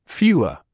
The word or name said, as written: fewer